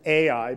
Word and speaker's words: Ai: It is the altar that he built, look at verse 8, between Beth El and Ai. Sometimes we pronounce it Ai, but in Hebrew this would just be pronounced I.